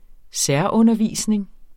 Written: særundervisning

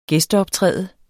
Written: gæsteoptræde

